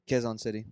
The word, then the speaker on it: Quezon City: A riot breaks out in Quezon City Jail. Did I say that right? – Quezon City.